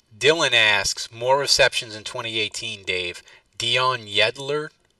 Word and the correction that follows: Yelder: Uh, Yelder.